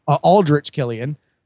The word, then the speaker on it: Aldrich Killian: Pepper, in one draft, slept with Alden Killian. Sorry, I've been getting his name wrong. Aldrich Killian.